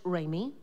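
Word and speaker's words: Remi: It's R-E-M-I. – Yes. – It's pronounced Raimi, but it's R-E-M-I. Good with an E at the end.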